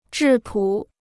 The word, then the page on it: 质朴: zhì pǔ